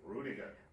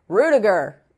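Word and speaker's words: Rüdiger: Rudiger. – Rudiger, if playing, you take the draw on that one.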